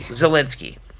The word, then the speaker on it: Zelensky: Zelensky.